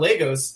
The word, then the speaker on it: Lagos: So the reason I made such a big deal about Lagos is they pronounce the name of the city incorrectly throughout the entire film. Like, nobody did research. They call it Lagos. That's not how you, that's, it's not an alternate pronunciation. The name of the city is Lagos.